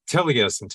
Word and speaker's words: Taliesin: Taliesin, yes.